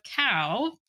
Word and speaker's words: Kao: So Cow, pronounced yes, like the animal. – Cow. – But spelled K-A-O.